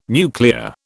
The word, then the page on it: nuclear: British English